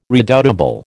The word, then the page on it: redoubtable: American English